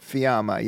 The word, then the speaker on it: Fiamma: Fiamma, yeah. – Can you spell that? – F-I-A-M-M-A.